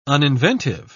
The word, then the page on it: uninventive: British English